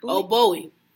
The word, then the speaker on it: Bowie: I know who he is, but how you pronounce David Bowie? – Bowie.